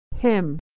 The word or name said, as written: hymn